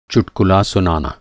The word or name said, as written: चुटकुला